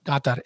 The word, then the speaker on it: Qatar: By the way, the correct way to pronounce Qatar apparently is Qatar. Qatar. It's like a soft G with a Qatar.